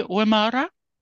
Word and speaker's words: Wymarra: Wymarra. Did I say that right? – Yeah. J. Wymarra, yes.